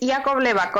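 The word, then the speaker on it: Iacobleva: Es Lacobleva, con I.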